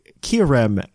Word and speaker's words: Kyurem: We talk everything here, Pokemon on the show, from the video game to the trading card game to even movies like Kyurem and the Swords of Justice.